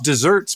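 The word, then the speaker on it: deserts: Also, Just Desserts, by the way, is desserts because you deserve them, and it is spelled with one S. It's not spelled with two S's, i.e. you get cakes and pies when you get your Just Desserts. You get your Just Desserts spelled like desert but pronounced dessert.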